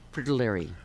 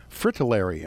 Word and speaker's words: fritillary: Is that the other one I know, this one here, is the Gulf, how do you say that, fritterly? – Fritillary. – Fritillary, okay.